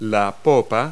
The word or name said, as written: stern